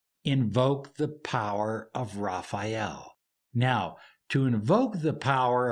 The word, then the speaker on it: Raphael: In the east is Raphael, and I'm going to use the classical pronunciation, Raphael. Raphael, visualize in beautiful yellow and purple energy, holding a caduceus or wand of healing power.